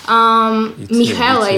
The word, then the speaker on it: Mihaela: Yeah, Mihaila is actually my name, but Mihaila, it's a little bit difficult for everyone, but you can call me Mel or Mishi, it doesn't matter.